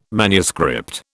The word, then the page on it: manuscript: British English